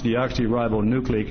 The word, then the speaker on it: deoxyribonucleic: The first thing, I learned to do was pronounce it. It's deoxyribonucleic acid, and I perfected it.